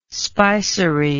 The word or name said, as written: spicery